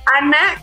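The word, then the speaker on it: Anna: Entonces es Ana, así estoy en mis redes sociales, ajá.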